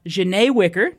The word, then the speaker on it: Zsane Wicker: We have Zsane Wicker.